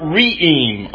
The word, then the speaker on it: re'em: Is the word, the original Hebrew, is the word re'em, R-E-E-M, two syllables though,